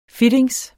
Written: fittings

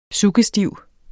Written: suggestiv

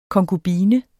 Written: konkubine

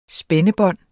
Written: spændebånd

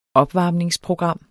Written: opvarmningsprogram

